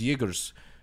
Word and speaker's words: Jaeger: Jaeger. With a J. There's a soft J.